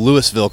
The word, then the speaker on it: Louisville: The S is silent apparently here. But they always say talk like you've had a few bourbons, and that's how you say Louisville properly.